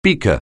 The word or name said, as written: beaker